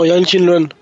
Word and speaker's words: Yang Xinluen: Xinluen Yang o Yang Xinlun. ¿Lo dije bien? – No, Yang Xinluen. – Xinluen. O sea, el loco escribió su nombre en inglés para que sonara como su nombre en chino. – Sí, Yang Xinluen.